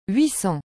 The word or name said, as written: 800